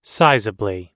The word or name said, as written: sizably